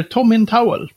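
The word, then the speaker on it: Tomintoul: att det där, det där eh, destillerinamnet, då ska man tänka Tom i handduk. Så det heter Tom in Towel.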